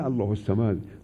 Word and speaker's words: الله الصمد: موقعی گفتن الله الصمد، های الله می خوره به صاد صمد، اون الف لام تلفظ نمیشه.